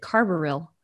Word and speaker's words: carbaryl: I say that differently. I've always pronounced it carbaryl.